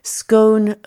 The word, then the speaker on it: scone: Scone rime avec home pour moi, sauf dans la prononciation, parce que j'ai fait partie des personnes qui disent scone comme gone.